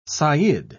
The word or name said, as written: sayyid